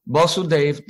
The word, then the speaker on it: Vasudev: That is Vishuddha Sattva, is known as Vasudev, that's V followed by a short A.